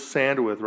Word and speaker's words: Sandwith: You can find her at Liz Sandwith, right? – Yep. – Liz Sandwith. And it's spelled, LizSandwith, right? Sandwith, WITH.